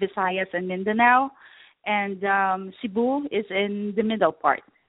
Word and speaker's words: Cebu: You're located in Cebu, am I pronouncing that correctly? – Yes, I'm in Cebu.